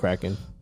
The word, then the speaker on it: Kraken: I don't even know how you pronounce it, but. – Kraken. – Kraken. That's what it is? – Yeah. – Kraken. – It's Seattle. Like the monster.